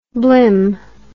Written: blim

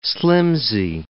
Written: slimsy

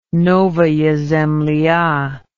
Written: novaya zemlya

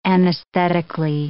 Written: anesthetically

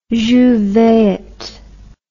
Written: jouvert

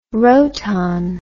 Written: roton

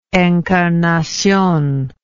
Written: encarnacion

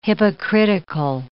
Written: hypocritical

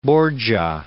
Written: Borgia

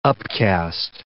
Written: upcast

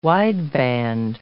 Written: wideband

